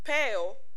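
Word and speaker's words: poieō: So the phrase banded together is pronounced, it's peo, and it's spelled P-O-I-E-O.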